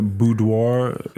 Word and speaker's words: boudoir: So how long ago were the boudoir?